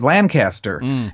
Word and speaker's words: Lancaster: And my very first hearing, I didn't say Lancaster, I said Lancaster. And the judge stopped me and he said, boy, if you want to practice down here, you better learn how to say the name of the county seat properly.